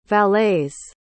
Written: valets

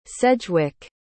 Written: sedgewick

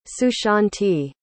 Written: sushanti

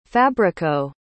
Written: fabrico

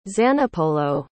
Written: zanipolo

